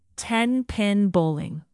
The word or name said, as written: ten-pin bowling